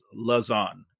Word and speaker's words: LeGendre: When you look at Lance LeGendre, and I just checked the pronunciation, it is LeGendre. So okay. Close. It's pretty similar.